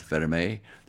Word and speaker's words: Vermeil: Well, the catalyst was admiration from my dad, Jean-Louis Vermeil, exactly pronounced Vermeil.